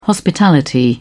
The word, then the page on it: hospitality: British English